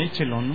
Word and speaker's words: Echelon: ¿Tú cómo la pronuncias? ¿Echelon o Echelon? – Pues a la mexicana, Echelon, ¿no? – Sí, Echelon, chinga su madre.